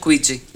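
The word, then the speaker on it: Kwid: Como é que é a pronúncia é? – Quid? Quid? Quid. Quid. Renault Quid. Isso.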